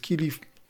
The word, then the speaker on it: Qilive: Firma jest Kilif, model 1297, pisze się Q-I-L-I-V-E i to jest, proszę Państwa, firma, która generalnie produkuje jakby na zlecenie sieciówki Auchan, także w tej sieciówce należałoby tego produktu szukać.